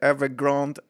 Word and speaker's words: Evergrande: Litet problem med uttalet bara om man säger Evergrande, Evergrande eller som jag sa i fem dagar Evergrade Johan. Till min bästa kompis sa att eh, inte uttalades så.